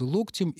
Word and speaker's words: локтем: Например, пишет она, коснуться локтем или локтем, как говорит наш инструктор по лечебной физкультуре. Нет, не изменилось. Локтем.